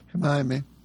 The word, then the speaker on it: Hermione: And that's Hermione Norris, if I'm pronouncing her name right. She's Hermione. – Hermione. – I can never... – Like from Harry Potter.